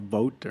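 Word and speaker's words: Voat: It was just called Vote, or rhymes with goat.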